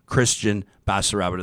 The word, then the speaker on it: Kristian Basaraba: Kristian Basaraba to the program, my man.